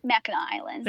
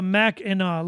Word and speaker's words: Mackinac: Mackinac Island. – There you go. Mackinac.